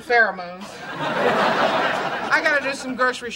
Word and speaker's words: pheromones: Pheromones.